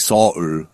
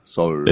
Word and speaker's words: Seoul: But we also mispronounce it. We say Seoul. – We say Seoul, but it's really Seoul. Seoul. – It's Seoul. It's two. Seoul. It's two. It's two syllables.